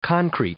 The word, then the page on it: concrete: noun